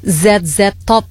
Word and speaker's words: ZZ Top: Di playlistnya Rock Weekend ada Z-Top. Bacanya gimana sih? ZZ Top, sebuah band hard rock dari Houston, Texas nih.